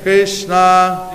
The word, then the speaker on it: Krishna: Pronounce each name distinctly. Hare Krishna Rama. It says each name is two syllables. Hare. I'm going to repeat that. Hare. – Hare. – Krishna.